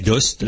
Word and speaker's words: Aoste: Chacun ses références, ils disent Valais d'Ost et euh, ouais mais ça s'écrit à Ost effectivement.